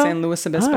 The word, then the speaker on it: San Luis Obispo: Louis rhymes with poopis, not Louis rhymes with poopy. Or if you want to go the whole brevity route, slow works. Well, yeah, I could go slow. So it's San Luis.